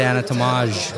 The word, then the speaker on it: Anatomage: We have the anatomage. Did I say that right? – Well done.